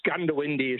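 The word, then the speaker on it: Goondiwindi: You say Gundawindi the way it's pronounced locally. – No, it's Gundawindi is correct.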